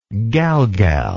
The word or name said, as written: galgal